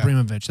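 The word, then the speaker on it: Abramović: Abramovich, that's how you pronounce it.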